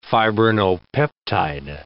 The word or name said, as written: fibrinopeptide